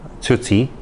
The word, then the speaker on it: Cixi: So when I was in India, excuse me, I read up on the last empress of China, Dowager Cixi, and I pronounce her name wrong all the time because I don't speak Chinese at all. Anyway, it's Dowager Tsuzi, and she just had this picture of her sitting on the dragon throne, and I wanted another one of that.